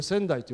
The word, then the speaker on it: 川内: 鹿児島にはですね仙台川って川が流れてるんですよ。これはえー、この宮城県の仙台閣じゃなくて、えー、川内と書いてこれ仙台と読